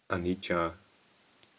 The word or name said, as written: anicca